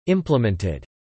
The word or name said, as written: implemented